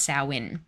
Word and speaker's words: Samhain: It was like an ancient festival that they had, and the festival was called, or it's pronounced Samhain, and it's actually a Wiccan holiday with very deep and satanic roots.